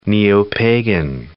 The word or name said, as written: neo-pagan